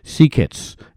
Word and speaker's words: Seeckts: Nathan Sekets, pronunciation challenged, Sekets, S-E-E-C-K-T-S, Sekets.